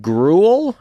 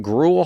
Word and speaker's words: Grewal: I'm going to be in Lansing, Michigan, which I don't think I've been to before, at Gruel. I think that's how you say it. Gruel Hall. G-R-E-W-A-L. Gruel Hall in Lansing, Michigan on, you think I would know the date of it, on April 19th.